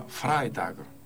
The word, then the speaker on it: Freitag: La pronunciación correcta sería Freitag. Imposible que yo apellido diga Freitag, puedan escribir. Si ya digo Freitag, que escriben cualquier cosa, imagínate.